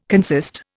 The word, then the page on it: consist: Indian